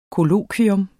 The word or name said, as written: kollokvium